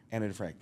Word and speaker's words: Anna Defrank: Anna Defrank.